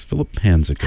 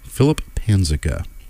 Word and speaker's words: Philip Panzica: Yes, Philip Panzica. – How would you spell that last name? – P-A-N-Z-I-C-A. Philip Panzica.